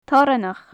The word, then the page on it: toranach: gaelic